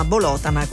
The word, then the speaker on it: Bolotana: Bolotana con l'accento sulla seconda O.